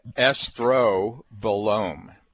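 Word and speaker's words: estrobolome: But look, you are an expert in hormones, and we're learning a new term today, and this is, and we think we pronounce it estrobilome. So, I think you did good.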